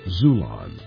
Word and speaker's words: Xulon: Get your paperback copy on Amazon, Barnes & Noble, or from my publisher, Zulon. That's spelled X-U-L-O-N.